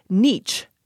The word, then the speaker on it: niche: saying something like niche, which rhymes with bleach. – Oh, I don't think I like that one at all. Here's one, N-E-E.